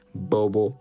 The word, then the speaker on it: Bobo: And yeah, if you want to catch me on my own personal Facebook page, Nash Workaholic Bobo, Nash N-A-S-H Workaholic, you know how to spell it, Bobo as in B-O-B-O.